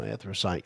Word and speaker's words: anthracite: And then there's that anthracite. Is that right? – I believe that's right. Yeah, anthracite coal, which I don't know what the difference is, but there is two different kinds there.